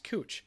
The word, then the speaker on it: Couch: And it says, there's this street in Portland that's spelled C-O-U-C-H. And most people, if you don't know, would pronounce it couch, because it's spelled like couch, but it's actually pronounced cooch, because this guy, John Cooch, was a founder of Portland. So it's called Cooch Street. So I point to this guy and the text that comes up says, "it's cooch, not couch," but it's spelled the same way.